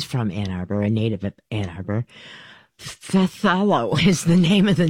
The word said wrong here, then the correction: Phthalo: I don't know how to pronounce it. But it is pronounced Thalo. P-H-T-H-A-L-O.